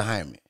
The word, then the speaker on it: Jaime: Is and how you spell Jaime? – H-I-M-E, I guess. – Yeah, it's J. Yeah. It's spelled J. Just like Jamie. – Oh, that's him. – Because J is like Jose Jesus.